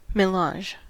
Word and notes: melange: American English

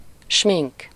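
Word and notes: smink: IPA: /ˈʃmiŋk/; Hungarian

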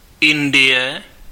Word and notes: Inde: France (Paris); [ɛ̃nd]; /ɛ̃d/

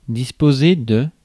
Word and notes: disposer: [dis.pɔ.ze]; /dis.po.ze/

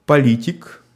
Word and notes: politician: /ˈpɒl.ɪ.tɪʃ.ən/; [ˈpɒl.ɪ.tɪʃ.ən]; /pɑl.ɪˈtɪʃ.ən/

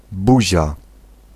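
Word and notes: mun: IPA: /mɵn/; Swedish

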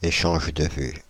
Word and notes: échanges de vues: France (Île-de-France); /e.ʃaʒ də vy/

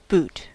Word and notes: boot: /buːt/

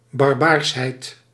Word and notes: barbarie: /baʁ.ba.ʁi/

